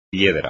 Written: piedra

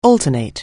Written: alternate

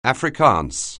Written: Afrikaans

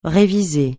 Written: réviser

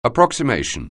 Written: approximation

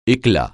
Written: éclat

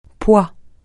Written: poids

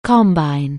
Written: combine

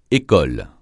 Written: école

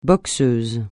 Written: boxeuse

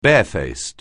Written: barefaced

